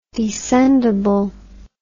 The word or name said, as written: descendable